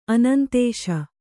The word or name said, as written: ಆನಂದಿಸು